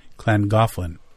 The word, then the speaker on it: Llangollen: But I do like how he put the name in North Wales pronounced Llangollen, because that's not how it looks.